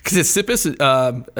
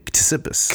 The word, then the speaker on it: Ctesippus: Ctesippus, aside from being very difficult to pronounce, because it's got that kapatau at the beginning. Ctesippus.